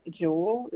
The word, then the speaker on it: Jewel: My name is Jewel. It's spelled J-E-W-E-L. And last name is Leggett, L-E-G-G-E-T-T.